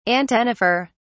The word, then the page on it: antennifer: British English